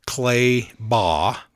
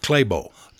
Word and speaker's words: Clay Baugh: and I was to mention a certain campground where an activity was going to happen the next day, and it was spelled Clay Baugh. – Mm-hmm. – That's how I would pronounce it. Right. – Yeah. But they pronounce it Claybo.